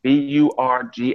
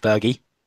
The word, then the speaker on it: Burgi: Berg. I'm going to mispronounce his name, but his name is Burgi. – Richard Berge.